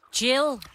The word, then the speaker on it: Jill: Jeg ved ikke, hvordan navnet udtales. Der står Jell på telefonen fra Roskilde. – Ja, hej. – Hej. – Hej. – Hvad er dit navn? – Jill. – Jill. Ah.